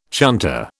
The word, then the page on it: chunter: British English